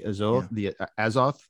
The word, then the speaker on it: Azoth: But you've mentioned the, please correct my pronunciation if I get this wrong again, the Azoth mandala. – I pronounce it Azoth, but you can do it how you like.